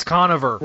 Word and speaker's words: Conover: Conover and Bowman's. – It's Conover. – Like, Conover.